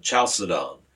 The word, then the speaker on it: Chalcedon: Well, he goes on to the Council of Chalcedon, which was in, did I pronounce that right? – Yeah.